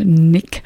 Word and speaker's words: 9ick: Now, Nick spelt with the letter, with the number 9, I-C-K, so Nick.